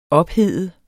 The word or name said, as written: ophede